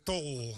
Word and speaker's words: Tol: Je wel goed uitspreken, want in Edam woont de familie Dol. – Okay. – En wij zijn de familie Tol. – Oh, je bent de Tol.